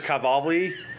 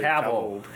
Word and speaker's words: Cavill: Hi, Mr. Cavalli. Cavill.